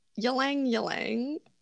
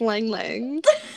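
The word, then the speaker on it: ylang-ylang: Then we have ylang ylang, which I think is pronounced lang lang.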